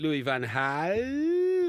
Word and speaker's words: Louis van Gaal: I think Louis van Gaal, that's the way his name's pronounced in his homeland of Holland, I think he's actually got him running up and...